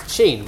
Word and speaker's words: Shein: Well, as the aforementioned Chinese online retailer, Shein, it's pronounced Shein,